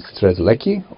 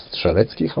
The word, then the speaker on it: Strzelecki: They date back to 1840, where Polish explorer Paul Edmund Strzelecki, or Strzelecki, how we pronounce it,